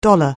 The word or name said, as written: dollar